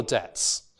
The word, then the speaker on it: debt: This word is pronounced debt. There's a t. There is nowhere to be seen a b, right? No one in the English-speaking world would say debt or something like that.